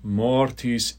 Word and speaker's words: mortis: Mortis in examine. And if you can't roll those R's, I would just go do a silent. Imagine there's a silent H there. So mortis. Mortis in examine.